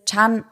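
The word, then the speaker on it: Chan (ちゃん): Eigentlich wird es Chan ausgesprochen und ist eine Art Verniedlichungsform.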